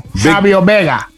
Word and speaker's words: Savio Vega: No. You said his name wrong. Savio Vega.